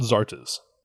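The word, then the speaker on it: Xarta's: It's called Zartas. – Zartas? Spell it. – X-A-R-T-A apostrophe S.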